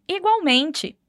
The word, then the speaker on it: igualmente: Muito cuidado com a pronúncia do L. Apesar de a gente ter o mesmo significado do português, não vamos falar igualmente, onde o L tem som de U, como igual. No espanhol, o L vai ter som de L mesmo, como na palavra lápis.